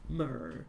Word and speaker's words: Mir: M-I-R. – Okay. – How would you say it? – Mir. Mir. I want to be... – The successful launch of... Mer comes just over 3 weeks after the American Space Shuttle Challenger disaster in which 7 astronauts died.